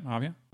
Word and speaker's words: Aviat: he ended up at I'll mispronounce it, but it's at Aviat. Our Aviat Husky. – Yeah. Mm-hmm. Yep. Aviat.